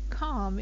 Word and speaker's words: calm: And there are some other times when you don't pronounce it, like in calm. If somebody's very calm and they're not stressed out at all, there is an L there, but you don't pronounce it.